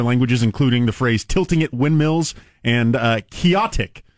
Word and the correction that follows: quixotic: uh, which means exceedingly idealistic, unrealistic, impractical. Quixotic. Ah, I said it wrong. – Quixotic.